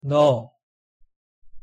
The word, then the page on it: 너: Korean